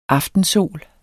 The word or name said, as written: aftensol